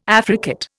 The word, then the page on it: affricate: Indian